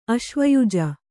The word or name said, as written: ಆಶ್ವಯುಜ